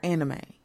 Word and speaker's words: anime: I don't know why I call it anime. Is it anime or anime? – You said it right. It's an anime.